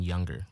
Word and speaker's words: Younger: So T-Y-R-E-E-C-E and then Younger, like the word.